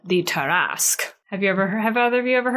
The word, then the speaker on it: Tarrasque: Say it again? – Tarrasque. – The Tarrasque? – How do you spell that? – Okay. A note on that in a minute. – Oh. – But what is in front of me right now is Tarrasque. It's French.